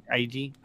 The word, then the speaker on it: Eiji: Is that how you say his name? Eiji? – IG? – Yeah, it's Eiji.